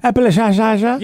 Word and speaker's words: Appalachia: Is that how you say it? Appalachia? – Appalachia. – Yeah, exactly.